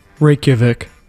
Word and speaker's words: Reykjavik: Reykjavik.